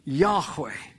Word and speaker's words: Yahweh: And so no one knows exactly how the ancient name of God is pronounced. However, the general consensus is that it is pronounced Yahweh.